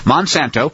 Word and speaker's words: Monsanto: Said co-counsel Brent Wisner, we're finally able to show the jury the secret internal Monsatan documents, he didn't pronounce it correctly, Monsanto, proving that Monsanto has known for decades that glyphosate, and specifically Roundup, which is basically glyphosate, could cause cancer.